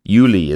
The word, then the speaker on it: Juli: Juli is how you say it in German.